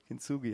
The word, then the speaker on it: Kintsugi: Ich weiß nicht genau, wie man das, wie spricht man das richtig aus? Kintsugi, ja.